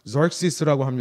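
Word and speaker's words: Xerxes: 그 밑에 왕을 보시면 이제 크세르크세스라고 하는 희한한 이름의 왕이 있는데요. 절시스라고 합니다. 영어로는요.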